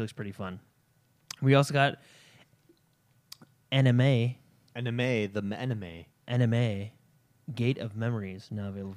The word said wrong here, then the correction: Anima: How are you pronouncing that? Anima. It looks like anima. – Anima. Anime. Oh, yeah, because there's no E, so anima.